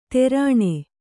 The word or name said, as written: ತೆರಣೆ